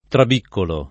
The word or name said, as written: trabiccolo